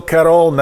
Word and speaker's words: Cuarón: Alfonso Cuarón. That is the Cuarón. That is the proper pronunciation.